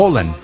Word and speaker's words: Haaland: Realmente Alan. Es Haaland. – Haaland. – Así se pronuncia en. Usted cambia correcto. La A por una O correctamente. Así es en noruego.